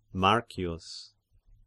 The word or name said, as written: Marcius